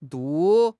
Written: du4

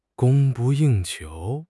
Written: 供不应求

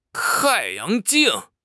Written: tai4 yang2 jing4

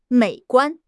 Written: 美观

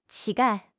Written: 乞丐

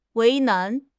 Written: 为难

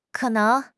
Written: ke3 neng2

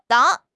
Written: 党

